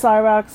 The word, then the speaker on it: Syrax: You see Syrax, who I want to pronounce Syrax, but it is pronounced Syrax throughout.